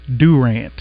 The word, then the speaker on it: Durant: Okay, it is Durant. Okay. – You told me that the other day. That's how the locals pronounce it. – Right, Durant. I say Durant, but if you're the locals, it's definitely Durant.